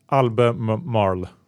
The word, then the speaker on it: Albemarle: Albemarle. A-L-B-E-M-A-R-L-E.